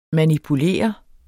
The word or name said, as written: manipulere